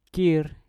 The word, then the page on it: kir: Mpur